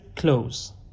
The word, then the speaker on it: clothes: توجه کنید که تلفظ clothes به معنی لباس با ز تموم میشه، خیلی آسونه. Clothes. خیلی از دوستان اینو اشتباهی clothes ترجمه میکنن یا تلفظ میکنن که کاملا اشتباهه.